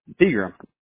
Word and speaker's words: Pegram: Pegram.